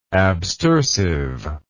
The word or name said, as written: abstersiveness